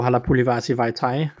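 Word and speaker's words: Halapoulivaati Vaitai: Jamen, øh, det er jo den gode Halapulivasi Vajtai.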